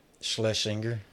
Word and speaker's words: Schlesinger: Schlesinger? – Schlesinger.